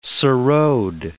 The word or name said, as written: sarod